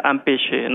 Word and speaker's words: empêcher: Por ejemplo, también es importante saber que el empacho, pues proviene, la palabra empacho proviene de impedicare, cristiano, y del francés antiguo, empêcher, se pronuncia empêcher, ¿no?